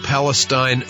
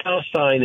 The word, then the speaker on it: Palestine: By the way, you pronounced it correctly. One of the few. you got the name right. – All right. – East Palestine is right in the area called Columbiana County, Ohio.